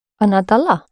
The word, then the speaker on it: дала: Accent the ending. – Она дала.